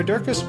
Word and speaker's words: Durkis: Sarah Durkis, which rhymes with circus,